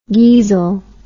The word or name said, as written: geezil